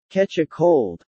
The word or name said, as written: catch a cold